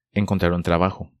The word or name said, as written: Encontrar un trabajo